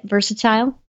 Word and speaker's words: versatile: V-E-R-S-A-T-I-L-E. – Maybe it's because I'm so versatile. – Yeah, that's how you say it? Versatile? – Yeah. – Okay. – Or versatile, either way.